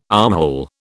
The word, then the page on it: armhole: British English